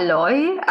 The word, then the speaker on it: Loi: Hallo, also ich bin die Sophia Loi. Ja, genau, also spricht man Deutsch, Österreichisch aus. In Italien haben es eh alle immer falsch ausgesprochen.